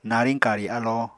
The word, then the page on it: nariñ-kari alo: Apatani